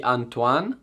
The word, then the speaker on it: Antoine: Sì, ma anch'io non sono sicuro ragazzi, la pronuncia ma è di Antoine, quello so che è giusto, Antoine.